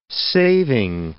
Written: saving

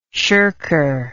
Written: shirker

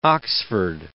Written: Oxford

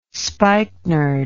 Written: spikenard

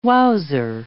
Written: wowser

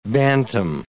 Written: bantam

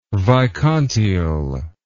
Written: vicontiel

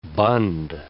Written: bund

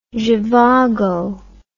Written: zhivago

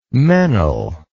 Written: meynell

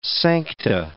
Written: sancta

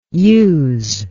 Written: yous

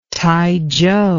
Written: Taichow

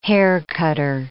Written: haircutter

